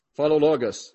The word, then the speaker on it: Philologus: Greet Philologus. How do you say that? Philologus.